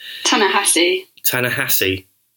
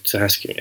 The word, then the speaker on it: Ta-Nehisi: So interestingly, this is a first-time comics writing debut from a guy called Ta-Nehisi Coates, which I hope I pronounced correctly. – Ta-Nehisi. – Mm. Okay, Ta-Nehisi, yeah, all right.